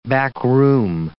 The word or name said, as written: backroom